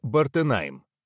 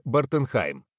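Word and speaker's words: Bartenheim: Французское произношение «Бартенайм», немецкое «Бартенхайм».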